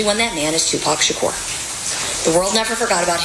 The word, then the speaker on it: Shakur: Tupac Shakur. – Shakur? – Yeah.